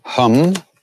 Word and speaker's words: hum: Om hum, like I hum a tune, although in India that's a short A, ha, a, hum, Hanumate Namaha.